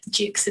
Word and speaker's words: Juckes: And just to tell everyone that Jukes is spelled J-U-C-K-E-S with a little, like a hidden C that just gets everyone and catches them out.